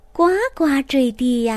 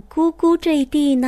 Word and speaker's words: 呱呱坠地: 应该念呱呱坠地呀，怎么念咕咕坠地呢？是不是你念错了呀？下面兔妈妈就来给你解释一下。这个字儿啊，有瓜字的读音，也有咕字的读音。读咕的时候，咕咕就是小儿的哭声。那咕咕坠地呢，讲的就是婴儿的出生。